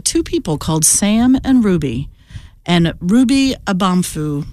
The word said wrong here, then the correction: Amanfu: did I say it right? Nope. Ruby Amanfu was the Ruby.